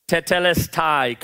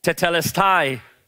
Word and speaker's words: Tetelestai: Tetelestai. Come on, say it with me. Tetelestai.